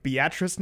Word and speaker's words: Beatrice: I went to a site to figure out how to say this correctly, Sandy. Beatrice, Nebraska. Not Beatrice, Beatrice. – Not Beatrice. – I think that's right. Beatrice, Nebraska. – Oh, it's Beatrice. I'll tell you, it's Beatrice.